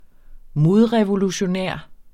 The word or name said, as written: MODREVOLUTION